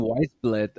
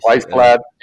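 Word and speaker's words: Wiesblatt: Also er hat ihn Weisblatt ausgesprochen, glaube ich. – Weisblatt, ja.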